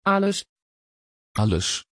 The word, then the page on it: Aless: Dutch